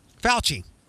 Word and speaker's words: Fauci: Fauci.